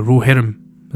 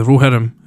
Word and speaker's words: Rohirrim: Another example, which is slightly more subtle, is the Rohirrim, who were, or the Rohirrim, is maybe how it's pronounced. It is how it's pronounced, in fact, is the Rohirrim, who were horse farriers.